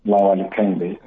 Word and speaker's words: Olawale Kehinde: Yeah, my name is Olawale Kehinde.